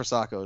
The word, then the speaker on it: Sakho: differently, but yes, uh, the one with the H, the silent H, gotcha. the silent killer. Right. But no, Diafra Sakho is the one that I think would be you know a decent tip for this game.